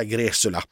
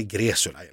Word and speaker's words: Gresula: Och den officiella versionen som vi har fått klarhet i, det ska vara med ett S-ljud, det vill säga Gresula. Sen ser ju det här då, ja, det skulle kunna vara polskt eller ungerskt eller något liknande, så att det uttalas säkert inte på det sättet på hemspråket. – Nej. – Men ja, vi kommer inte längre än så, utan i Sverige är det Gresula.